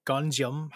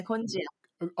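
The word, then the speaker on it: Gonjiam: Yeah, Gunjeom. – Oh, did I say it wrong? Gunjeom. – No. – I said it wrong.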